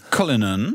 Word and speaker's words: Cullinan: Cullinan.